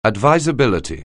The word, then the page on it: advisability: anglais